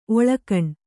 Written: ಒಳಗಾಣ್

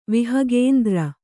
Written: ವಿಹಗೇಂದ್ರ

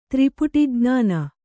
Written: ತ್ರಿಪುಟಿಜ್ಞಾನ

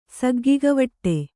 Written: ಸಗ್ಗಿಗವಟ್ಟೆ

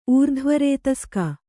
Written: ಊರ್ಧ್ವರೇತಸ್ಕ

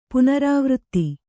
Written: ಪುನರಾವೃತ್ತಿ

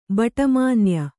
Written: ಭಟ್ಟಮಾನ್ಯ

